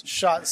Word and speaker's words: Schatz: So did you say Schatz? – Schatz, see? Schatz, Schwartz, whatever you want. – Yeah. Schatz, that's a lot easier.